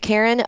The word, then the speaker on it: Carin: It's, oh my gosh, you guys, it's called the Karin console, but it's spelled C-A-R-I-N. So that's hilarious. – Yeah. The Karin bone inlay console.